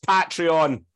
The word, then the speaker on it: Patreon: Would you really expect to pronounce it correctly? – It's pronounced Patreon.